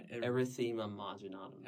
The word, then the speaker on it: Erythema marginatum: Erythema marginatum. – Erythema marginatum.